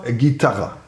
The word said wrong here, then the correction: Gitana: Naja, Gitana, aber ist nicht so schlimm.